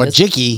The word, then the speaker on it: Wojcicki: Anne Wojcicki, is what her name Wojcicki.